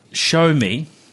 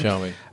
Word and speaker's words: Xiaomi: So, Zio, show me. Now, I always forget how to pronounce this thing. XIAOMI, for those that are wondering. – Show me.